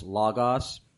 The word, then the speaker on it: Logos: And you may be wondering, folks, why I'm pronouncing L-O-G-O-S, Lagos. It's because I was corrected by the aforementioned Dr. James R. White of Alpha Omega Ministries that no matter how anybody chooses to pronounce it today, and Logos is probably the most common, he said the correct way to pronounce it is Lagos.